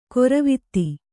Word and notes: ಗೊರವಿತಿ: Kannada

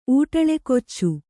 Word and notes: ಊಟಲೆಕೊಚ್ಚು: Kannada

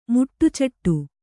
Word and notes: ಮುಟ್ಟುಚೆಟ್ಟು: muṭṭu ceṭṭu

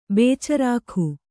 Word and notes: ಬೇಚರಾಕ: bēcarāka

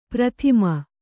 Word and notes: ಪ್ರಥಿಮ: prathima

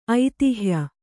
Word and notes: ಐತಿಹ್ಯ: aitihya

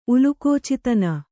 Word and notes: ಉಲಕೋಚಿತನ: kannada

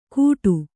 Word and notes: ಕೂಟು: Kannada

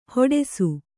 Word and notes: ಹೇಡೈಸು: kannada; hēḍaisu

